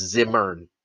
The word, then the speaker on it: Zimmern: But now I want to do a deep dive into the food because Andrew Zimmerman has it's not Zimmerman. I just learned that too. It's Zimmern.